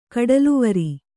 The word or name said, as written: ಕಡಲುವರಿ